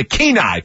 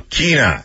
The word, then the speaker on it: Kenai: You just hate it when I butcher Alaska pronunciations. – Right. – It's the Kenai Peninsula. – The Kenai.